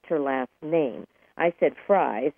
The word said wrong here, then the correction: Fries: It's spelled F-R-I-E-S, but it's pronounced Freeze. So I wanted to correct myself on that. It's Sue Freeze.